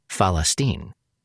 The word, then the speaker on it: Falastin: Instead of calling it Philistine or Filastin, the traditional Arabic name of the region, the editors opted for the local Palestinian Arabic pronunciation of Falastin.